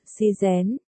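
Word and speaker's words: si rén (死人): Nói chạy là để kiêng, hỷ thần, thần vui, tiếng Trung đọc là, si xén, người chết, tử nhân, thì đọc là, si rén.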